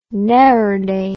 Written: neerday

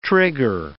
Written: trigger

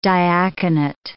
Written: diaconate

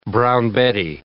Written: brown Betty

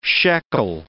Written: shekel

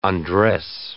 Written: undress

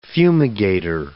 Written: fumigator

